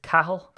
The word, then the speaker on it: Cahill: Irish surnames, you know, Cahill. It's wrong. It's Cahill. That's how it's actually supposed to be pronounced here back in the motherland.